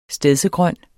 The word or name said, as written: stedsegrøn